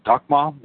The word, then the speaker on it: Dakhma: The lawsuit filed Wednesday in the District Court of Oklahoma County claims Satanic leader Adam Daniels and his religious organization, the Dakma, I guess that's right, pronounced Dakma, is D-A-K-H-M-A, of Angria Maino, have, quote, unlawfully taken possession of the property belonging to the Roman Catholic Church, specifically a consecrated host.